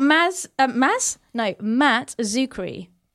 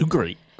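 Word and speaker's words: Matt Czuchry: It's got Matt Zuccari. Is that how I said his name, right? Yeah, it's Matt Zuccari.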